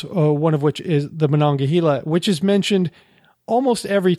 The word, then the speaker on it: Monongahela: And one river that he said four times, he said Monongahela, which is the Monongahela.